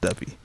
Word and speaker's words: Duppy: So as far as first, as far as a response to what Drake Duppy freestyle, that's what it's pronounced, Duppy.